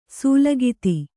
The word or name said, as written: ಸೂಲಗಿತಿ